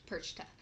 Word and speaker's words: Perchta: It is because the T comes after the CH. Perchta.